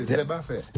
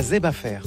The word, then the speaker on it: Zebafer: Et en créole, c'est Zebafer. Zeb, comme herbe, affaire. – Zeb. – Parce qu'il est chargé en fer aussi. – Ah, Zebafer.